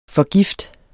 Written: forgift

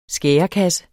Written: skærekasse

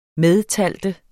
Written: medtælle

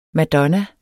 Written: madonna

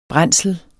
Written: brændsel

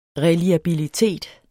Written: reliabilitet

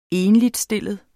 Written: ENLIGSTILLET

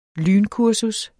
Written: lynkursus

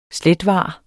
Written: slethvar

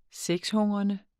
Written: sexhungrende

